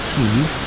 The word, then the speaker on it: Kiev: eventually in Kiev. I'm hearing about Kiev on the news, unfortunately. Kiev, or Kiev, the Ukrainians pronounce it Kiev, that's the great center of Ukraine now, and we're hearing about it because of the war between Ukraine and Russia right now.